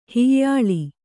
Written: ಹೊಯ್ಯಲು

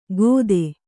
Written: ಕೊದೆ

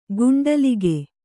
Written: ಕುಂಟಲಿಗ

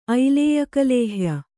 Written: ಐಲೇಯಕಲೇಹ್ಯ

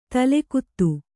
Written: ತಲೆಗುತ್ತ

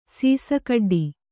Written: ಸೀಸಕಡ್ಡಿ

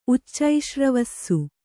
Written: ಉಚ್ಚೈಶ್ರವಸ್ಸು

